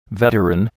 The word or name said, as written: veteran